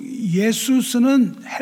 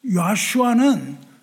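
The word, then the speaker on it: Yeshua: 예수 예수스는 헬라식 발음이고요. 야슈아는 히브리식 발음이에요.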